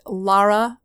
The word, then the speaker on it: Lara: So all the way you can always remember it is just Lara rhymes with star.